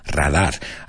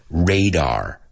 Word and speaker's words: radar: Esto de stealth es indetectable con el radar. Acuérdate en inglés, radar. Se pronuncia radar. Se escribe igual, pero se dice radar. Radar.